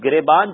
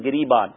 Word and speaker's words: گریبان: تو جو تلفظ درست کروائے گئے تھے تو گریبان جو ہے وہ گاف پہ زیر کے ساتھ درست ہے, زبر کے ساتھ درست نہیں ہے.